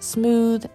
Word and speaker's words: Cmooth: That is my good friend, goes by the name of Smooth, and that's spelled C-M-O-O-T-H, but pronounced Smooth.